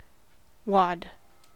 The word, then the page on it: wad: American English